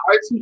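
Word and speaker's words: R2Bees: And then we have R2Bs. That's how you say it? – Right? Yeah, R2Bs.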